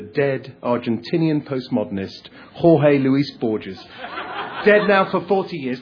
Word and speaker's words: Borges: I asked her how to pronounce her name after this poem, and she said it's Borges. Sarah Borges rhymes with gorgeous.